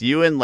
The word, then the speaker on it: Yeun: Yuen, like Ewing without a G.